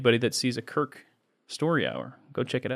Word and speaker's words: Kirk: So this is for the best Christian actor who got his start in Growing Pains and whose first name rhymes with Dirk. It's a fiercely competitive category. But this year, the award goes to Kirk Cameron.